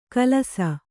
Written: ಕಲಸ

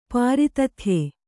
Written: ಪ್ರತಿದೇಯ

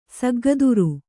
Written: ಸಗ್ಗದುರು

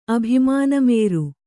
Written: ಅಭಿಮಾನಮೇರು

